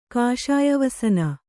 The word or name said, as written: ಕಾಷಾಯವಸನ